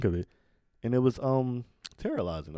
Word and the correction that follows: terastallizing: or to oh terastallizing. – Terastallizing. That's how you say it.